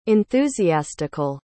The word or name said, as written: enthusiastical